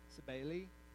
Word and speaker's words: Cybele: And one of the requirements for those who were, and actually I might not be saying it correctly, it's C-Y-B-E-L-E, Cybele, Cybele, anyway, one of the requirements in this particular, from this particular deity, quote unquote, was the emasculation of male children.